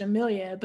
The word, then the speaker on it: Jamelia: And my first name is not difficult to pronounce. It's Jamelia, but there are so many variations of that I oftentimes am just like, okay, like, all right, you pronounce it the other way, it means the same thing.